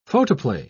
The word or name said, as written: photoplay